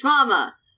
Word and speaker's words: Ranma: Vomin 1/2. So, Vomin? Is that how you say his name? Wayman? Ramen? – I thought it was Ranma. – It's Ranma. Why did I...